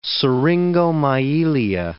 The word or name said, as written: syringomyelia